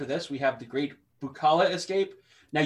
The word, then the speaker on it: Bookala: And I say Bukala because it's like boo. It's spelled B-O-O-K-A-L-A. – Yeah. – Bukala.